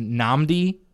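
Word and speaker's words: Nnamdi: I really enjoyed the album by, I believe it's pronounced Namdi, his album Brat.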